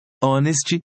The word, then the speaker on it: honest: Exemplos em que o H é mudo: honest, honor, hour.